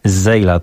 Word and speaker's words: Zsela: Ma 29 lat, jest z nowojorskiego Brooklynu i występuje pod pseudonimem Zeyla. Tak to się czyta, ale pisze się troszkę bardziej w skomplikowany sposób, bo to Z-S-E-L-A.